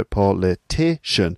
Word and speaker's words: politician: But then a person, that the person noun, that's politician. So politician. So the word stress is now on the third syllable.